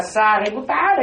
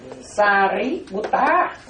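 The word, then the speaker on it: Sāriputta: Quý tử ta gán đọc là Sariputta đi, nha. Sariputta, nha. Quý tử gán đọc, cái từ đọc tiếng Việt nó, đọc tiếng Việt nó người Mỹ nó nghe nó không hiểu.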